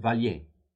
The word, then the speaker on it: Vallier: Welcome again, actually, Professor Valier. – Thanks so much for having me on. – I should give the proper French pronunciation, Valier, right? – Yes, although it's quasi-Americanized. It's just Valier.